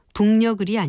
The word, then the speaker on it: 북녘: 북녘이라는 말에서 두 번째 음절의 받침을 기역으로 알고 계시는 분들도 있을 텐데요. 이것은 키역 받침을 씁니다. 따라서 그 뒤에 모음이 연결될 때에는 북녘을이 아니라 북녘을이라고 발음합니다.